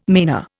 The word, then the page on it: manor: Indian